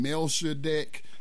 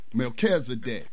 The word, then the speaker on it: Melchizedek: But Hebrews 7 talks about, how do you pronounce his name? Melchizedek, the priest. – Melchizedek, yeah.